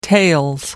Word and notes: tails: British English

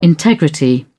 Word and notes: integrity: British English

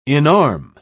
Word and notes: inarm: British English

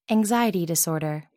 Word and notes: anxiety disorder: American English

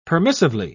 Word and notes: permissively: British English

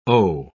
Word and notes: o': British English

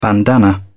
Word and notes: bandana: British English

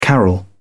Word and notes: carol: British English

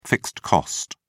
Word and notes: fixed cost: British English

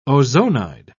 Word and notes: ozonide: British English

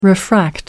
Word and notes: refract: American English

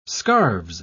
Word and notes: scarves: British English

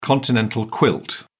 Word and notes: continental quilt: British English

